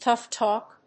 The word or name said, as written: tough talk